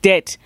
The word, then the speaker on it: debt: So, I mean, Gerald, I don't know why this word has a silent B because it breaks bank debt.